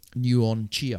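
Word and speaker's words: Nuon Chea: Nguyen Chia. I don't know if I've pronounced that right. That's the person. – That's right.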